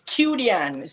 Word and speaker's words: Quidian: It's Quidian. So Quidian. – The I after the U is silent, right? – All right.